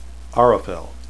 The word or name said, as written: arafel